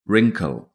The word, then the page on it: wrinkle: British English